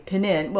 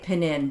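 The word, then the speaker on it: Pnin: I know, Pinin sounds like a panini. Yeah. – You know, like a Italian sandwich. So uh, we're going to say it Pinin, but we are well aware that the Russian pronunciation is Pinin.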